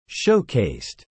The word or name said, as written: showcased